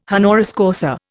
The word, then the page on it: honoris causa: Indian